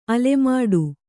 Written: ಅಲೆಮಾಡು

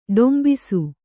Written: ಡೊಂಬಿಸು

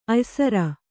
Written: ಅಯ್ಸರ

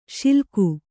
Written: ಶ್ಲಾಘಿ

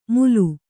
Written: ಮುಲು

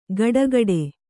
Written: ಗಡಗಡೆ

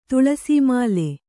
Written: ತುಳಸೀಮಾಲೆ

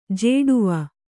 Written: ಝಡಾವು

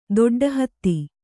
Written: ದೊಡ್ಡಹತ್ತಿ

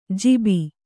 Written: ಜೀಬಿ

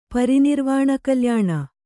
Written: ಪರಿನಿರ್ವಾಣಕಲ್ಯಾಣ

